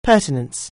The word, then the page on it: pertinence: anglais